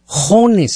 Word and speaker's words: Jones: O como Juanes o como Cajones, pues le quita la C y la es queda Jones.